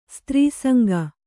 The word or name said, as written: ಸ್ತ್ರೀಸಂಗ